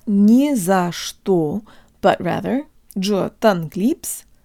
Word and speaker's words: не за что: guys, and today you'll learn how to pronounce the phrase не за что, or don't mention it. Although there are three little words in this phrase, it functions just like a single word, with only one stressed syllable, не.